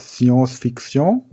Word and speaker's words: Science-Fiction: Oder Science-Fiction? Oder wie spricht man das aus? – Science-Fiction.